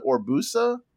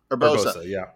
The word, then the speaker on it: Urbosa: So, also honorable mention, who is it Orbusa? Is that the one from the desert? – Urbosa.